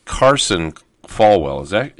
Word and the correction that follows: Carlson Farwell: Is that right, Kimberly? Did I say it right? – Close. Kimberly Carlson Farwell.